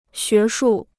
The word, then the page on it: 学术: xué shù